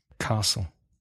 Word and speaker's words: castle: And another common mistake by Spanish speakers, the silent T in Castillo in English. – Castle. – Mm-hmm. Castle.